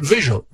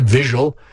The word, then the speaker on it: vigil: So before the show, the cast had a candlelight vigil. What? Vigil. Yes, V-I-G-I-L. That's how you pronounce it, not visual.